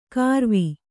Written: ಕಾರವಿ

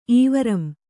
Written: ಈವರಂ

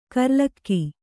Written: ಕೂರಲಗು

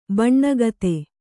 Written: ಬೋನಗಿತ್ತಿ

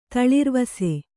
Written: ತಳಿರ್ವಾಸು

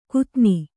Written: ಕುತ್ತನಿ

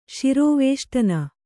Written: ಶಿರೋವೇಷ್ಟನ

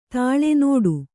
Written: ತಳೆ

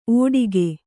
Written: ಓಡಿಕೆ

